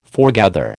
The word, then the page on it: forgather: American English